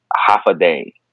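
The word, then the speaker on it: Håfa adai: Half a day. Is that how I say that right? – Yeah, that's how you say it.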